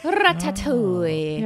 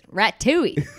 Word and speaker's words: ratatouille: I think it's Ratatouille.